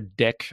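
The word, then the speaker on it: deque: And but yeah, the collections module has this thing called dequeue. Or deck, I think some people pronounce it.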